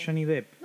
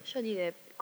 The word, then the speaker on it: Johnny Depp: No sé cómo se dice. Es Johnny Depp. – Nunca supe, ¿sabes? – Es Johnny Depp. – Johnny Depp, confirmadísimo. Pero bueno,